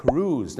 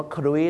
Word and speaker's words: cruise: ก็คือคำว่า cruise นั่นเองครับถูกต้องครับ cruise เหมือนทอมครูซนะฮะชาวไทยนะครับบางทีไปออกเสียงว่าครุย